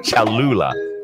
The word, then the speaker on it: Cholula: Cholula.